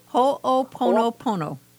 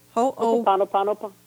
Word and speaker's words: Ho'oponopono: How do you pronounce it? – Ho'oponopono. – Ho'oponopono.